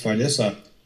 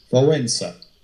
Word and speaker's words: Wałęsa: Lech Walesa wird er in Deutschland genannt, spricht, spricht natürlich ganz anders aus. Einen polnischen Namen auszusprechen ist für Deutsche fast nicht möglich. Er heißt mit richtiger, mit richtiger Aussprache Wałęsa. Das klingt ein bisschen anders als Walesa.